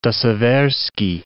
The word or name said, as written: De Seversky